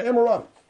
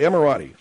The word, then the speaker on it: Emirati: Oh, let me look at it. – Don't put it in my face. Read it over there. – Yeah, Emirati. – All right, Emirati. I guess you pronounce it like it's a car.